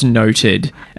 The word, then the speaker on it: NODD: It's pronounced Noted.